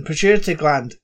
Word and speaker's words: pituitary: Once they are retrieved, Olivia makes a connection to the how do you say that word? Pituitary gland. – Just like that. It's fine.